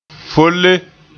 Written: foole

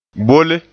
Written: bolle

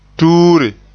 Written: tuure

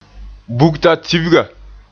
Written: bugtatibga

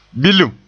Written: bilim

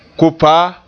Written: kopaa

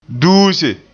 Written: duuse

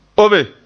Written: obe

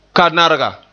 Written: kanarga

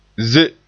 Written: ze

